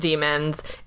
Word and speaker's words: daemons: It's just pronounced demons in the same way that Philip Pullman demons are, which is shenanigans all around.